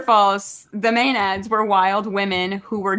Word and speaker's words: Maenads: And then the answer, I don't even know how to pronounce this. Maenads? – Oh yeah, Maenads.